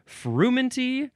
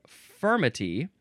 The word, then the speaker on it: frumenty: Oh, well, it's spelled a couple of ways. Farmenty, that's the second one. So the first one is frumenty, frumenty. The second way is spelled furmity, and that is fermity.